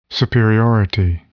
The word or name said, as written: superiority